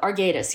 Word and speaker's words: Arguedas: Arguedas, like your parents' former friend. Did I pronounce that correctly? – I don't think of it as ambivalence, though. Arguedas, yeah. No, but I don't think of it as ambivalence.